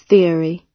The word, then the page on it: theory: British English